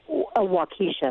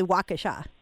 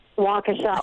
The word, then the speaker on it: Waukesha: And if you head east on I-94, you get to the town of Waukesha. Am I pronouncing that correct? – Actually, Waukesha. – Waukesha. Oh, that's even better.